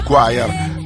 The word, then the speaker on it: Choir: Tanda Choir o Choir, mi dicono è più corretto pronunciarlo Choir. Tanda Choir, domani sera dal vivo al Castello Sforzesco per la Festa della Musica.